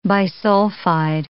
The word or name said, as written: bisulfide